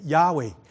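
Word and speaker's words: Yahweh: 这一个 L-O-R-D 在希伯来文就是亚伟，这个亚伟是什么呢？啊，如果我们拼音的人我们都懂，对不对？ Y-A-H 亚亚亚 W-E-H 乌埃伟亚伟，亚伟这一个发音就很像希伯来文里面说我是，我是，我是 I am，这一个就是上帝的名字。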